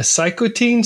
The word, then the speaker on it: cytokine: She has what's called, and again, I'm learning more as we go, I think everybody is, but a cytokine storm? – Yeah, cytokine storm. – How do you pronounce that? – Cytokine.